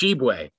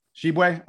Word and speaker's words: Tshiebwe: Um, and then the, the biggest of them is they brought in uh, Oscar Tishwibi, um, whose name I'm probably mispronouncing, from West I Virginia. think it's Shibwe. – Shibwe? Okay. – Yeah, Shibwe. Oscar Shibwe. – Shibwe.